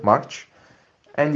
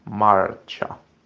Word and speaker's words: marcia: It's like you pronounce March, you know, the month. So January, February, March. And you put the A at the end of the word. So Marcha. Cha. Marcha. Marcha.